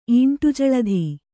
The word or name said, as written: ಈಂಟುಜಳಧಿ